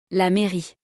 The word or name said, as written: la mairie